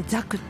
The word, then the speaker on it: 作: サクって書くんですよね、作品のサクなんですけども、そう書いてザクって読むんですよ。